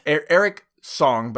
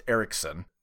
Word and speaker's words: Eriksson: Isn't it Eric Song? – Eric Song, but pronounced Eriksson.